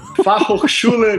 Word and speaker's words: Fachhochschule: Fachhochschule Münster.